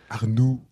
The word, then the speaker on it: Arnoux: You said it in the French way. Exactly. Adrien Arnoux. Yeah. Is that the right pronunciation? – Arnoux. – Arnoux, that's the ooh sound I can't do.